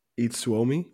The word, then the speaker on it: Itsuomi: And it is Yuki and Itsuomi.